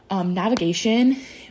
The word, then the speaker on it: NVGTN: Navigation, but it's spelled NVGTN.